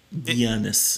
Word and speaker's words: Giannis: How come the commentators on ESPN always call him G- uh, Giannis, like with a Y, Giannis? – They don't say Giannis. Is it like Euro? Giannis.